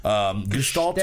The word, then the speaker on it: Gestalt: Gestalt is the way that you're supposed to pronounce it, but I'm sure they're just Gestalt.